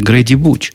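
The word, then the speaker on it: Grady Booch: это произносится Грейди Буч.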